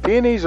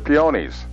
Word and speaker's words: peonies: The pronunciation, is it peonies or peonies? – They're both right. – They are? – Yeah. – Okay. – If you look it up in the dictionary, they're both in there.